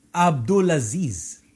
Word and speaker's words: Abdulaziz: How do you say his name? Because I'm going to wreck it. – King Abdulaziz.